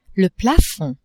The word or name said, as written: plafond